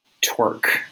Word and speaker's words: torque: So if you look up a word like torque, for example, T-O-R-Q-E, how do you pronounce that word? Well, you don't like sound it out letter by letter, really, because that doesn't work for those last two letters, right? Those letters are silent.